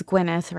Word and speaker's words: Gwyneth: I mean, in one version of this woman's life, Gwyneth Paltrow is just, or it's Gwyneth, right? That's how she pronounces it.